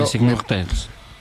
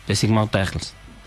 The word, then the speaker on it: Les Immortels: Les Immortels, no sé si se pronuncia así en francés, pero... – Les Immortels.